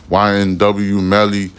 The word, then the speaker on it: YNW Melly: He covers that little rapper, YFM Melly, no, I'm saying it wrong. YNW Melly trial case that got, I think he got a hung jury or a mistrial.